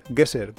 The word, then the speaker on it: GeoGuessr: Sí, se escribe Geo, de, como de tierra. G-U-E-S-S-R, Guesser, como adivinador de, ah, de lugar de, adivina, vale.